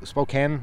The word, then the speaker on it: Spokane: Basically the train split in Spokane.